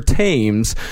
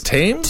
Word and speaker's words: Thames: So Julia Martha Thomas, she was a twice widowed 60-year-old recluse, lived a few miles upstream from London on the River Thames. – I believe it's pronounced Thames. – Thames? – Is it Thames? – Thames. – Thames. Okay.